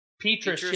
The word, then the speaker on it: Petras: Number three, not really sure how to say this last name, Spencer Petras? – Petras, yes, sir, Petras.